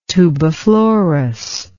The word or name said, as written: tubiflorous